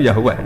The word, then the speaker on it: Yahweh: Ya paham ya, Tuhan Yahudi tunggal nyu Yahweh, napa? Ya. Iku nak teng ejaan Latin Y besar A H besar. W-E-H Yahweh.